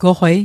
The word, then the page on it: goqwei: Mi'gmaq